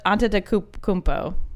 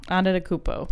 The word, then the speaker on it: Antetokounmpo: Giannis Antetokounmpo. – Giannis Antetokounmpo. Antetokounmpo.